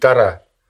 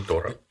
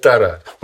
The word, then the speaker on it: Тора: То есть, вот есть, ну, только не проси меня, чтобы я тебе рассказал про, так сказать, из чего состоит Тора, правильно говорить, Тора. – Не Тора. – Европейские евреи приучили нас говорить Тора. Но все, так сказать, иудеи вот верного хода, они говорят Тора.